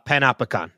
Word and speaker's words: Panopticon: Well, and I think that the prison, uh, the prison cell, the Pantopicon. Did I say that right? – Uh, Panopticon. Yeah, Panopticon. Okay.